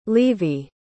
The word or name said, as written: levey